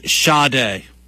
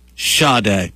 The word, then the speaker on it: Sade: Yeah, so apparently it is Sade. Sade. Pronounced S-A-D, or spelled S-A-D-E.